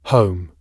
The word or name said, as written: home